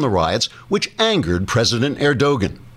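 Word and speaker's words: Erdoğan: pronounced Erdogan because the G is silent, as in angster.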